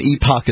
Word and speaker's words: epoch: An epoch is an era, a length of time. – I wasn't thinking E-P-I-C, I was thinking E-P-O-C-H. – That's epoch. – Isn't that pronounced epic? – No. – Epoch.